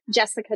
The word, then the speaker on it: Jessicah: My Instagram handle is at Jessica Travis. And my name is spelled J-E-S-S-I-C-A-H and then T-R-A-V-I-S.